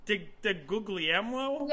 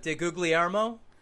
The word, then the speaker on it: DiGuglielmo: De Guglielmo? – It's definitely not googly. – Like, the second G is silent.